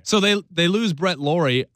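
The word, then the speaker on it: Lawrie: Put Jed Lowry at second base, and apparently, at least people pronounce his name right. I mean, now we don't have to hear people saying Brett Lowry all the time.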